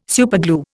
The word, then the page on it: superglue: Indian